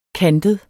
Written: kantet

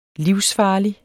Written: livsfarlig

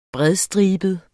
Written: bredstribet

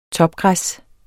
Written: topgræs

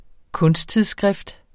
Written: kunsttidsskrift

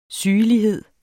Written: sygelighed

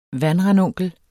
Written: vandranunkel

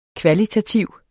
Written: kvalitativ

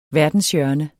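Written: verdenshjørne

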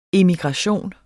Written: immigration

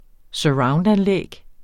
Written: surroundanlæg

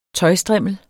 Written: tøjstrimmel

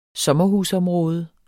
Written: sommerhusområde

